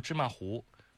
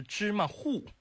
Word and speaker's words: 芝麻糊: 而且这个东西我们读了一辈子读芝麻糊，后来才发现它的正确读音是芝麻糊，这是学播音之后才知道的。